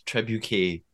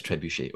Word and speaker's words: trebuchet: I thought it was trebuchet, but it's trebuchet.